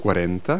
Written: forty

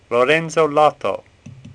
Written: Lotto

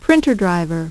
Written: printer driver

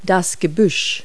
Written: bush

